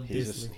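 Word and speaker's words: Dissly: Is that his last name? – That's how you pronounce it. Will Disley.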